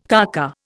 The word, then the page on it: kaka: Indian